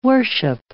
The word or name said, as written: worship